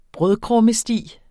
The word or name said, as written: brødkrummesti